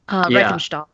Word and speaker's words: Riefenstahl: Lenny Riefenstahl, if I remember, if that's the pronunciation. – Lenny Riefenstahl. Riefenstahl.